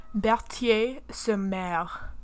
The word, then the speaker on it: Berthier-sur-Mer: Berthier-sur-Mer.